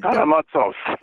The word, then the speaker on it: Karamazov: I mean, Karamazov.